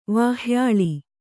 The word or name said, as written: ವಾಹ್ಯಾಳಿ